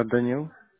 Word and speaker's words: Danylo: Daniil. – Okay. Can you spell it for me, please? – D-A-N-Y-L-O. – Y-L-O.